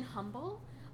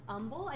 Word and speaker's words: humble: I was like, yes, she doesn't pronounce her H in humble. Humble.